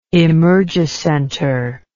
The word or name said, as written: emergicenter